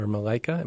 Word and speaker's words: Malaika: When you think, when you play this song, Angel or Malaika, am I saying that right? – Yeah, yeah.